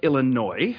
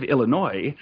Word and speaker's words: Illinois: The state of Illinois, I'm told that's how it's pronounced. Us Canadians really don't know whether to pronounce the S or not. But the state of Illinois seems to have, from the reports I'm reading, put a ban on all electronics in their landfills.